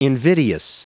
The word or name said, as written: invidious